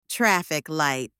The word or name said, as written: traffic light